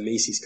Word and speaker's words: Mises: The Mises Caucus. I'm sorry, the Mises Caucus, sometimes mispronounced that.